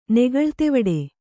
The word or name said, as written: ನೆಗಳ್ತೆವಡೆ